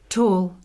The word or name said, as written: tall